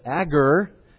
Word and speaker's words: Agur: We are told in Proverbs 30 that, and you can pay your money and pronounce it any way you want, but Agur, A-G-U-R, Agur, son of Jekel, wrote Proverbs 30.